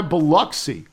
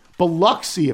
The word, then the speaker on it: Biloxi: Despite the spelling, there is no O sound in Biloxi. It's not ox. Try Biloxi. Biloxi if you want to get better.